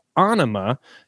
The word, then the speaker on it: Ænima: I heard an interview with an Australian radio DJ pronouncing it Ænima, where Adam Jones of Tool, for Dan's sake, clarifies that Ænima, just like Rory says it, is correct.